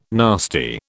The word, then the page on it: nasty: British English